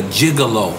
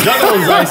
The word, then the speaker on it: Juggalo: So now, I want to get into your gigolo Did I say it right? – Juggalo.